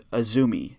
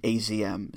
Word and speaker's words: AZM: It's Azumi, current high-speed champion, and it's spelled A-Z-M, but it's pronounced Azumi, not A-Z-M.